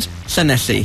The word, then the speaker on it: Llanelli: Llanelli.